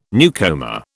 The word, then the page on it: newcomer: British English